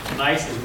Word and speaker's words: gneiss: And so when you get this under very high metamorphic conditions, you'll get actual gneisses, which is spelled G-N-E-I-S-S, pronounced gneiss.